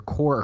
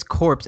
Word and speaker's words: Corps: The S is silent and it comes out to just be core. – It is corpse as in Peace Corps or United States Marine Corps.